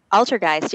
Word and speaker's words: Altergeist: how do you pronounce it? Altergeist? – Altergeist, yeah. It's German for like the oldest ghost, Altergeist.